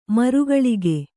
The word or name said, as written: ಮರಕಾಳಗ